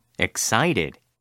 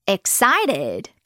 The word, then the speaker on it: excited: 이거 발음할 때 excited 라고 해도 되고요. t 사운드를 그냥 부드럽게 르, 르처럼 excited 라고 해도 좋답니다. excited.